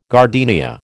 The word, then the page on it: gardenia: American English